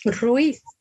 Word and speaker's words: Ruiz: Ruiz.